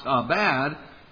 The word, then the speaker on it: abad: That word for cultivate in Hebrew is pronounced abad, and the word for keep or guard is shamer.